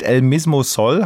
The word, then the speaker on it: El Mismo Sol: 2015 gab es den ersten Riesensommerhit El Mismo Sol.